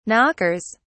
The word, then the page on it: knockers: British English